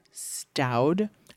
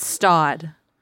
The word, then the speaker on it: Staud: Actually, Stoud, is that how you say it? Stoud? I always said Staud. Yeah, because it's definitely Stoud.